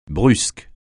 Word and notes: brusque: francais

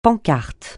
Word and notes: pancarte: francais

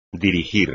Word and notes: dirigir: espagnol

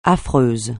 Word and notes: affreux: French

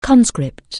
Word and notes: conscript: anglais

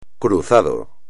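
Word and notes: cruzado: español

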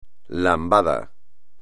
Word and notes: lambada: espagnol

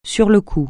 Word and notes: sur le coup: French

